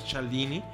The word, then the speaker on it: Cialdini: En este libro, el doctor Cialdini, se escribe Cialdini, pero tengo entendido que se pronuncia Cialdini, nos habla de seis principios básicos, generales y universales para lograr la persuasión de las personas.